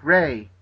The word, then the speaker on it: Rea: It's Ray, actually. – Ray, just like Ray.